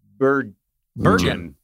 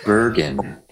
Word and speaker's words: Bergen: Doesn't rhyme with sturgeon. – Bergen. – I did it right. Bergen. I did it right the first time. – Bergen.